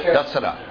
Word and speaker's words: Dasara: So at the end of the 9 days of Navaratri, then they have the Dusra. Is it called, am I saying it correctly? Dusra? – Dasara.